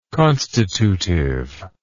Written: constitutively

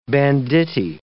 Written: banditti

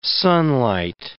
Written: sunlight

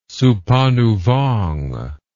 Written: Souphanouvong

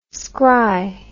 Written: scry

